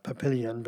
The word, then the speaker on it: Papillon: Reading the word says papillon, but it's papillon is how you pronounce it.